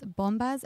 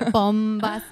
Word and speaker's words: Bombas: So it's bombas as in B-O-M-B-A-S.